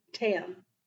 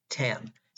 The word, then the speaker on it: Taem: Tam. – Tam. Desu Tam.